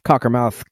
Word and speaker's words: Cockermouth: Yeah, I think that's Cockermouth, Cumbria in the UK, right? Right? – Yes, thank you.